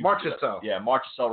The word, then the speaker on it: Marchessault: Jonathan Marcel, I can't pronounce his name. – Marchessault, Rob.